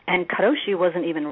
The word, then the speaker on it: karoshi: It's called, I know it's pronounced a couple of different ways, karoshi or karoshi. Have you ever heard of that? – No. Now, is that, do you get that at the sushi bar? No, I'm just kidding. – Let's hope not, and I'll tell you why. Because the word, I'm going to pronounce it karoshi, means death by overwork.